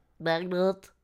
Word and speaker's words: Bagnolet: Bagnolet. I don't know if I'm saying that. – Yeah, just Bagnolet? Say it like you got something stuck in your nose. – Bagnolet. – That's it. Yeah, nailed it.